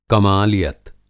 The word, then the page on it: कमालियत: کمالیّت